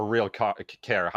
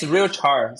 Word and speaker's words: RealChar: So are you sort of like insinuating that it gives, like, going through, how do you, is it RealChar or RealCare? How do you say it? – Yeah, it's RealChar. So real time, okay. Yeah, character. Yeah.